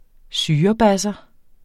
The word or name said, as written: syrebasser